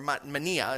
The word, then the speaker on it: mania: The word actually is the Greek word mania. or mania, it's actually pronounced, but it's spelled the same way.